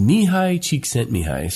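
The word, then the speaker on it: Mihaly Csikszentmihalyi: It actually was developed by his name is funny. It's pronounced Mihai Csikszentmihalyi.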